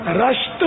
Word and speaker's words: رشت: اچھا وہ صحیح تلفظ جو ہے نا, امی جان کا مزار, دوست پاک کی امی جان کا مزار, وہ ایران کے صوبہ گیلان کے صدر مقام رشت میں ہے. راہ پر زبر بتا رہے ہیں جی رضا, رشت شہر ہے.